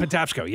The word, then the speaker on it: Patapsco: Patapsco? – Patapsco, yes.